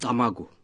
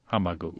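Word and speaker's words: Camagu: Now, the lead character in your novel, Kamagu, have I pronounced his name right? – Well, that will do. – Well, how do you pronounce it? – Kamagu. – Kamagu. – Yeah.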